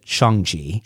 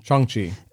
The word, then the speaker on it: Shang-Chi: If I attribute a Iron Fist martial arts move to Shang-Chi, or I believe you're supposed to pronounce it Shang-Chi. – Shang-Chi.